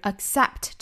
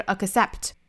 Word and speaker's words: accept: 那我们还需要注意一点的，就是 accept 这个单词里面的这个 k 的发音大家不要爆破出来，不是 accept，而是憋这么一下的爆破 accept，失去爆破 accept，不要把它爆破出来，是发成 accept，而不是 kss，是 kss， accept。